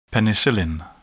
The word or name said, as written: penicillin